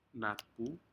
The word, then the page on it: natpu: Aneityum